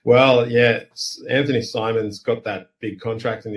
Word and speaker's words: Simons: Yeah, and I mean, like, look, the surprising thing for me this year has been the play of Anthony, you know, Simmons, right? Simons, how do I say it? – Simons, I think, yeah.